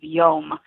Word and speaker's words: yom: And the word that they've come up with is yom. And so I love it because it rhymes with home.